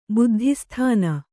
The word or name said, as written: ಬುದ್ಧಿಸ್ಥಾನ